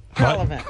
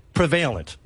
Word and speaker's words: prevalent: Prevalent.